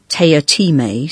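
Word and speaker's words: Teatime: to a deeply disturbing young assassin called Mr. Tea Time, pronounced Taya Time, so he insists.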